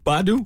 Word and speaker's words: Badu: Are you talking about Erykah Badu? – Did I say it wrong? Badu? – Okay, you finally got it.